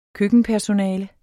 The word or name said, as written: køkkenpersonale